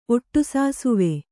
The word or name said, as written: ಒಟ್ಟುಸಾಸುವೆ